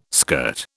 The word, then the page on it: skirt: British English